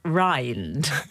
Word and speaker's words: round: I was struck by the fact that round was actually pronounced rind.